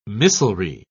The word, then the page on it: missilry: British English